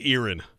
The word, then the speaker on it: Erin: I'll say Erin. – You nail it. – Yes. Okay. Not Aaron. – Not Aaron. Erin. – Erin.